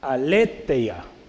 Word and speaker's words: Aletheia: Aletheia, ¿sale? Con acento en la E.